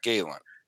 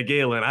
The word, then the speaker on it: Gallen: Okay, so I'm saying it wrong when I say Zach Gallen. – Yeah, Galen. I don't know where you got the Galen from.